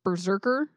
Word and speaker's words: BRZRKR: In production, he's in a thing called, so this is how it's spelled, all caps, BRZRKR, Berserker.